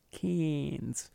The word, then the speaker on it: Cairns: It's pronounced Cairns.